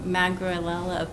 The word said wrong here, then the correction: Magrela: How do you say that? – Magrela Fever. – Oh, pretty close.